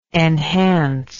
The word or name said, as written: enhancive